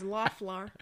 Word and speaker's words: Loeffler: First of all, her name is Loeffler. – The O is silent.